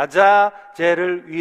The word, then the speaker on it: 아자젤: 또한 자갈 위에는 아사셀을 위하여 원래 발음은 아자젤입니다. 아자젤을 위하여라고 써 있습니다.